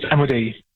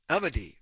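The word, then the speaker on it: Amodei: This is Anthropic CEO Dario Amodi. Have I pronounced his name correctly, by the way, Professor? – I think it's Amodi. – Amodi. Okay, thank you.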